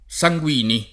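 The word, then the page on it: Sanguini: italiano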